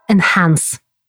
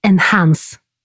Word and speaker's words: enhance: Naprawdę? Tak się to wymawia? Bo widziałem je często, natomiast zawsze się zastanawiałem, jak je wypowiedzieć i pomijałem dlatego, że wydawało mi się, że to zawsze spieprzę. A to po prostu jest enhance.